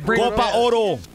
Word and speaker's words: Copa Oro: Wait, how's it pronounced? What is it? – Copa Oro.